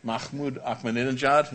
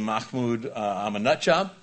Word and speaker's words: Mahmoud Ahmadinejad: You have a president of Iran, Mahmoud Ahmadinejad, is how you pronounce his name. If you prefer to pronounce his name Mahmoud Ahmadinejad, you can do that too.